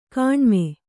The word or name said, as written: ಕಣಿಮೆ